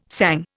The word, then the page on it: sang: Indian